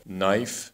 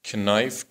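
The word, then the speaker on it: knife: تلفظ کلمات انگلیسی مثل نایف به معنی چاقو نی به معنی زانو و نالج به معنی دانش رو اینطوری تلفظ میکردند کنایف کنی کنالج و بقیه اون کلماتی که با کی هم شروع می شدن ولی کی خونده نمی شد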